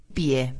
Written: pie

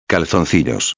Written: calzoncillos

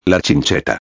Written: la chincheta

